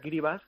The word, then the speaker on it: Kiribati: Vanuatu tiene varias islas con volcanes y tal, pero Tuvalu y Kiribati, que se pronuncia Kiribati, son muy bajitos, son los dos países que con más riesgo tienen de quedar debajo del mar.